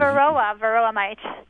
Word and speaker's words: varroa: And did I get that right? It's the varroa mite? Like varroa mite. – Spell that, please. – V-A-R-R-O-A.